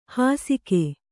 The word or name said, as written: ಹಾಸಿಕೆ